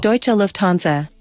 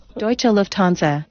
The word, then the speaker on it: Deutsche Lufthansa: 来，我们听一下他的这个名字的正确读音是什么啊？Deutsche Lufthansa。 Deutsche Lufthansa。